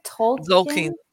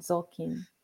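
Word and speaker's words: Tzolkin: The how do you say that again? Zolkin. It's T-Z-O-L-K-I-N. Zolkin.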